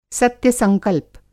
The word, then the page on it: सत्य-संकल्प: Hindi